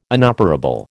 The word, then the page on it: inoperable: American English